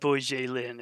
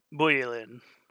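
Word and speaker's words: Bojelin: forgot that J's are pronounced like Y's. So it's not Bujelin, it's Bujelin, which sounds much better, and like, because it's much closer to buoyant.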